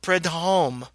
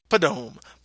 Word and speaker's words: Prudhomme: The turducken came to North America as a Cajun product from Paul Prudhomme. You're the French guy. How do I pronounce that? Prudhomme? – I don't even know what you're saying. You're so far off. Prudhomme.